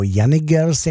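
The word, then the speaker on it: Janick Gers: a ještě samozřejmě Jenny Girls, nebo Jenny Girls, jak jsem taky postřehl, že se vyslovuje. Jako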